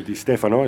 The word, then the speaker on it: Di Stefano: Or Di Stefano, as some of us call him. – If you're pronouncing it incorrectly, yes.